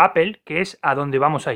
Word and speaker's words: Apple: Bueno, esa es la forma correcta de pronunciarlo en inglés. Apple.